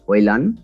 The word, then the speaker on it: Højlund: So I believe, Sean, the pronunciation is Rasmus Højlund, so that you don't pronounce the D.